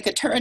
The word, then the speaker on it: Keturah: Like if I open up to any page in Catarin Odyssey, what am I seeing? – Well, in the case of Keturah, well, actually it's the Keturah Odyssey, but as long Keturin.